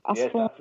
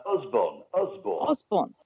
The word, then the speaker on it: Usborne: Is it Usborne or Usborne? – Yes, Usborne. – Usborne, okay. – A little bit like Usborne, as like in Ozzy Osbourne, but with a letter U, Usborne.